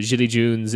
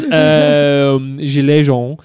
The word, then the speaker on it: Gilets Jaunes: But I guess that kind of explains also like why the Jilly Junes in like... I'm never going to stop pronouncing it that way. – The Jilly Junes. – Gilets Jaunes.